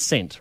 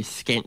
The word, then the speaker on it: scent: Remembering there's different ways to pronounce certain letters and that there's silent letters in English, which letter is actually silent in scent? Is it S or is it C? – Because you can have scent. You're saying scent, sir, for scent. – And you can have scent anyway. Scent. It's the same thing. Scent. – I will say one thing. If you say it phonetically, skent sounds like a really bad insult.